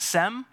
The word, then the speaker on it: Shem: The word Shem can also be pronounced Sem.